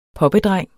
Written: poppedreng

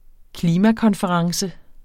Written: klimakonference

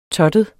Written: TOTTEDE

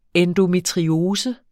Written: endometriose

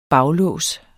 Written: baglås

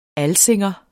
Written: alsing

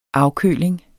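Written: AFKØLINGEN

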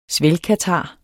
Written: svælgkatar

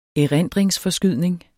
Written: erindringsforskydning

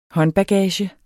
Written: håndbagage

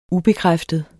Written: ubekræftet

